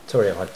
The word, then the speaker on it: torrefied: Torrefied. T-O-R-R-E-F-I-E-D.